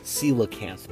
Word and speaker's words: coelacanth: It's pronounced coelacanth, Mom.